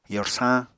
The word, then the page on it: yorsañ: apatani